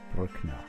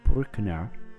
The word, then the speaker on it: Bruckner: Anton Bruckner has no umlaut over the U, so it is pronounced U, while Oscar Bruckner does have an umlaut over the U in Bruckner, and the U is pronounced U. So it's the difference between U and U.